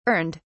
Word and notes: earned: British English

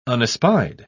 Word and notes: unespied: British English

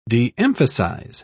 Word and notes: de-emphasize: British English

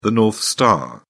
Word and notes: North Star: British English

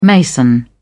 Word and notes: mason: British English

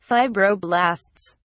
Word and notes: fibroblasts: British English

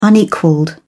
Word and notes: unequalled: British English

